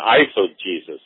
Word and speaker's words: eisegesis: So it's exegesis and eisegesis, right? – Yeah, eis is spelled E-I-S-E-G-E-S-I-S. Eisegesis, actually it would probably be pronounced eisegesis in Greek, but we would say eis probably.